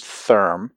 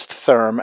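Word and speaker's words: Thyrm: The company name, it's spelled T-H-Y-R-M. I think it's pronounced Therm, so I'm going to go with that.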